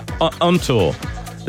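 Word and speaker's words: ANOTR: It's A-N-O-T-R, Ontor.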